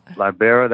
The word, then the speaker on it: Libera: Libera, that's how you pronounce it? – Yes.